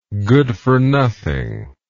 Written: goodfornothing

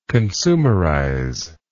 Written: consumerization

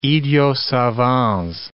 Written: idiot savants

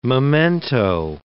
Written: memento

